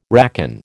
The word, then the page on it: racon: American English